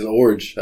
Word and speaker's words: Iorg: We're excited about this one, and it's called Spiritual Leadership, and it was written by Jeff Yorg. I never can say his name. It's spelled I-O-R-G. – Yeah. – How do you even say that? – I think you're right. It's Orge. – Orge. Silent I.